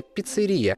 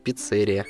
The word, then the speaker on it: пиццерия: Если хотите говорить на итальянский манер, тогда ставьте ударение «пиццерия», а если следовать словарю, рекомендованному радиодиктором, то нужно говорить «пиццерия».